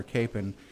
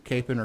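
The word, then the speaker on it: Kapaun: Well, we're glad to have you today because you are what we consider to be the expert on Father Capon. And I just read an article that said the Wichita Diocese doesn't care how you pronounce it, Capon or Capon.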